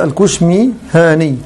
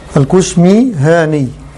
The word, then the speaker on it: الكشميهاني: والثامن ابو الهيثم محمد بن مكي الكشميهاني تنطق هكذا الكشميهاني الكشميهاني هذا الضبط صحيح له تمام.